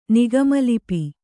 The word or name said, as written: ನಿಗಮಲಿಪಿ